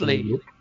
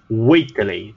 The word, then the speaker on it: Whateley: nessa carta, que vocês brigaram tanto, nem era uma carta tão importante assim, é uma carta de um tal senhor Waitley. Vou passar a pronúncia correta. Waitley. – Waitley. W-H-A-T-E-L-E-Y.